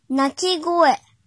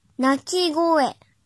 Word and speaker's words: 鳴き声: 训读的时候读作会、会、会，比如鸣叫声，鳴き声、鳴き声、鳴き声。